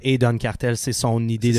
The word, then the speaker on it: Don Cartel: Don Cartel D-O-N Cartel, ça s'écrit comme ça se prononce.